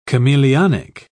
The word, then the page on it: chameleonic: British English